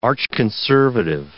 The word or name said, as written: archconservative